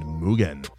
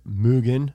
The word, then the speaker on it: Mugen: And there's been a lot really of hungry discussion over the years of how to properly pronounce the word Mugen. – Yeah. – And the founder of Mugen came out with a video, and it is Mugen, not Mugen. – The correct pronunciation is Mugen, not Mugen. Mugen is really, really uncool.